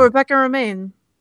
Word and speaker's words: Rebecca Romijn: Oh, Rebecca Romijn.